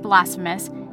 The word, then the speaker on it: blasphemous: So I was right. It is pronounced blasphemous.